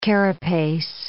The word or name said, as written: carapace